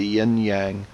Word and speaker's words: yin-yang: We have the very famous black and white circular image that most Americans know as the yin-yang or the yin-yang. How do you say those two words? – Yin-yang. – Yin-yang. – The A is the ah sound instead of the a sound.